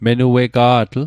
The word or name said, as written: menuega'tl